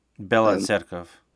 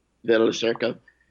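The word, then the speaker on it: Bila Tserkva: how do you say that? – Bela Tserkov. – Bela Tserkov.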